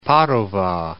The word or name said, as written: Padova